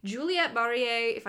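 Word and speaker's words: Juliet Marillier: It was published in like 2000, and I'm telling you, Juliette Barrier, that is how you pronounce her name, she walked so that Naomi Novik could run.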